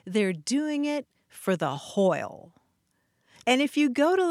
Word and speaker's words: hwyl: This story takes place in Wales, and the word they're using is spelled H-W-Y-L, hoyle. – Nice. W is a vowel. – W as a vowel, as you see in Welsh.